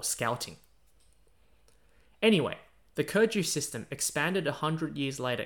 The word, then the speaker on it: 科举: Keju, pronounced in Chinese as Keju, means subject recommendation, originating from the use of testing people on various subjects to recommend them to office.